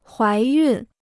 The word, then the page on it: 怀孕: huái yùn